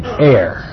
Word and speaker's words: heir: The first word here, A-I-R, and the second one, H-E-I-R, are pronounced exactly the same. Heir.